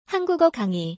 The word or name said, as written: 한국어 강의